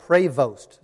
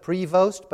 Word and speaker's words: Prevost: His name is actually Robert Prevost. So it looks like Prevost, but it's pronounced Prevost, Robert Prevost.